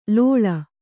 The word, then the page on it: ಲೋಳ: lōḷa